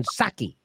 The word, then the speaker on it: Psaki: You're not supposed to pronounce the P. Jen Psaki which is what I like to get.